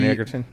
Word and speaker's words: Egerton: Taron Egerton.